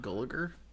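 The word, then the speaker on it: Gulager: Is that how you pronounce his name? – Gulliger? – Yes.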